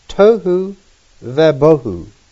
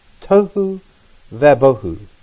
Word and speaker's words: tohu vabohu: And the way this is pronounced is tohu vabohu. Tohu vabohu.